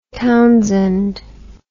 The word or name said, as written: Townshend